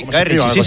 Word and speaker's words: Guy Ritchie: Lo he dicho bien, Guy Ritchie. – Sí, Guy Ritchie, sí.